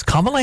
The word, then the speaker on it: Kamala: Kamala Harris? What is the proper... – The proper pronunciation is to think of a comma. – Okay. – And a law.